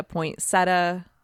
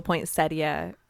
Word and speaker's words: poinsettia: Yes, some people call it a poinsettia, some people call it a poinsettia. According to the Google, poinsettia is correct, but either pronunciation is actually technically acceptable because I think it's like 50-50 on the population for who pronounces it how.